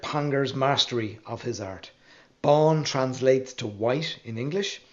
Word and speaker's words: Bán: Pangor Bán, spelled B-A Father N and pronounced Bon as in Don, is the white cat that you see on the bottle, and he's the master of his art, or the happy hunter.